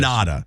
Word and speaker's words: Kannada: And they looked at you and they went, we need this guy to speak Kannada, is that how you say it? – Kannada, I believe. – Kannada.